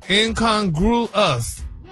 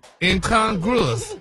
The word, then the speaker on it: incongruous: No, he had the last syllable wrong. He just now you got the right syllables and it's broken down for you. – Yeah, break it down. – Incongruous. – There you go. – Okay. And what is the word? – Incongruous. – It's an adjective, right? – They said it is. It's an adjective. And it's pronounced how? – Incongruous.